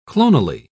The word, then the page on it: clonally: British English